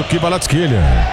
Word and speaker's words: Kvaratskhelia: Kvitch e Kvaratskhelia. O Aston Villa descontou com o Brian Maggio, nasceu em 2009. – Gostei da pronúncia aí do Kvaratskhelia.